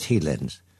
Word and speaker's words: Highlands: And we go to the Highlands of Scotland, pronounced Heelands.